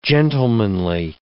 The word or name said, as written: gentlemanly